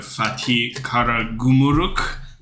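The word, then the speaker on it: Fatih Karagümrük: Pardon me, the new coach, Volkan Demirel, former head coach of Fatih Karagumruk. I don't know how I'm pronouncing on that, but... – Sounds right.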